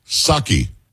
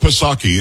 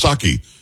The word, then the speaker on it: Psaki: Ed, do me a favor, say, repeat after me. Psaki. It's not Psaki. It was just Psaki. You didn't pronounce the P.